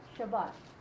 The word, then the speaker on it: Shabbat: They would say Shabbos instead of Shabbat. Yeah, good Shabbos to you instead of good Shabbat.